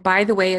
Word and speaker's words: Bytheway: So my name is Laura, by the way, and by the way is my actual last name. It's pronounced just like the prepositional phrase.